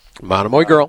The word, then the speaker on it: Monomoy Girl: So a couple races go, I lose one, and then I did hit, I don't even know how to butcher the name, Monomoy Girl. – Monomoy Girl.